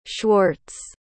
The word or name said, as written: schwartz